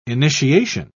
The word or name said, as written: initiation